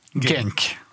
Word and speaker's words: Genk: Genk.